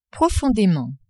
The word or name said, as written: profondément